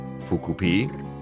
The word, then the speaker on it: Fukupi: And yet, Fukupi, as it's pronounced in Japanese, has become a joke across social networks.